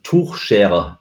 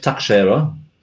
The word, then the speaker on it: Tuchscherer: And his name would be pronounced Tuchscherer in Deutsch, in Germany. – Yeah. – But he calls himself Tuchscherer.